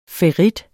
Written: ferrit

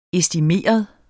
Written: estimeret

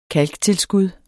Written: kalktilskud

